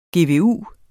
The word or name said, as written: GVU